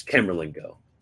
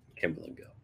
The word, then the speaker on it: Camerlengo: How do you how do you pronounce that? Carlamingo, Car- like the cardinal dude, who is Camerlengo. It's Camerlengo, Camerlengo.